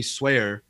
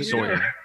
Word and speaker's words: Sawyer: So recently, I don't know if you saw the news, the actor that plays Ralph Dibny, Elongated Man, Hartley Swear. Is that how you pronounce it? Sawyer.